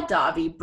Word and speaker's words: Dahvie: I think it's Davi. It's spelled D-A-H-V-I-E.